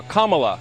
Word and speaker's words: Kamala: Her name is pronounced comma, like the punctuation mark, la, Kamala.